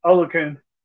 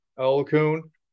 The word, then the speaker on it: Oluokun: Oluokun. – Oluokun.